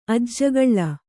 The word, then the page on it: ಅಜ್ಜಗಳ್ಳ: Kannada